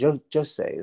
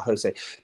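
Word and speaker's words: José: I can remember now, I'm thinking back to the documentary, said Jose, that's how you say it. Not Jose. Jose.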